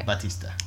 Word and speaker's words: Batista: Batista.